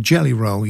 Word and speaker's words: Jelly Roll: Now, a guy that I've been keeping a track on for the last probably about a year now, a great guy called Jelly Roll. Yeah, I did say that right.